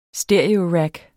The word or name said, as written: stereorack